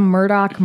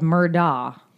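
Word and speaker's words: Murdaugh: Now, the name looks like it's spelled Murdaw. – Yeah. – But as Mandy explained in her podcast, everyone knows it's Murdoch in South Carolina.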